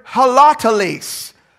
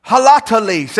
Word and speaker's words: holoteles: In fact, when he says the word wholly or entirely, it is the Greek word holoteles. Holoteles. Everybody, uh, let's learn Greek together. Say holoteles. Holoteles. There it is. It's spelled H O L O T E L E S in the transliteration.